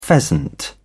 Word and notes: pheasant: British English

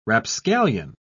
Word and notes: rapscallion: British English

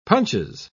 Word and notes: punches: British English

